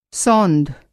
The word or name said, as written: सौंध